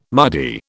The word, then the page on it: muddy: British English